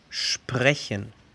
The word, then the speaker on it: sprechen: Vemos la palabra de diversión, Spaß, S-P-A-S-Z. Número 9. Las combinaciones SP y ST. En estas dos combinaciones la S cambia su pronunciación a sh. El ejemplo anterior, spas. O otra, el deporte, S-P-O-R-T, sport. O hablar, sprechen. Un ejemplo con ST sería Stein, piedra. S-T-E-I-N, Stein.